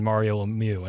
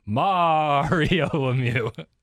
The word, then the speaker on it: Lemieux: And something I didn't say would have got me in trouble with the hockey fans when you said it's a long A on Mario Lemieux, and I almost said Mario Lemieux.